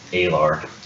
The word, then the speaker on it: Aelar: Alar. – Spell that. – A-E-L-A-R. – A-E-L-A-R.